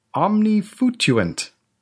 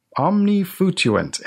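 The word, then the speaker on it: omnifutuant: And the stress is on the foo, so it's omnifutuent.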